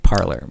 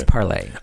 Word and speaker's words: Parler: I thought it was parlay. It's parlay, isn't it? – Yes, but I like making fun of them calling it parlor, but okay. It's parlay. – I bet a lot of them pronounce it parlor.